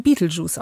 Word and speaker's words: Betelgeuse: Denn so hieß der titelgebende Geist in der Horrorkomödie von 1988, angelehnt an den Stern Betelgeuse, der im Englischen Beetlejuice ausgesprochen wird.